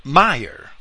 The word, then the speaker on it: Meyer: Others, as Meyer, I'll pronounce this Meyer because it's German, it's spelled like the English word major.